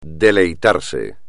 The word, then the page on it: deleitarse: espagnol